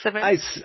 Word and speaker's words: Simonds: So it's Simonds, though, right? Like you say, it's Simonds? – I pronounce it Simonds. It's about 50% of how people pronounce it.